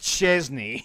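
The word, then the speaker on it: Szczęsny: you got the Wojtek Szczesny, you know, is how it's pronounced. And I probably still am not pronouncing it properly, so.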